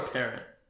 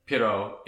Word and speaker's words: Pierrot: produced Perot or Parrot. – Pierrot.